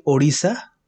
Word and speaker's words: Odisha: So I am from a state called Odisha. – Odisha? – Yeah, Odisha. It's actually Odisha, O-D-I-S-H-A.